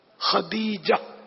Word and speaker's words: خدیجہ: اچھا وہ تلفظ خدیجۃ الکبریٰ یا خدیجہ بھی کہہ دیں، خطے تا نہیں ہے دال ہے، خدیجہ خدیجۃ الکبریٰ. – اچھا خدیجہ بھی ہے تلفظ اس کا شاید، واللہ اعلم. تو معروف تو یہی ہے خدیجۃ الکبریٰ، تو خدیجہ کہہ دیں تب بھی چلے گا.